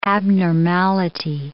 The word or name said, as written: abnormality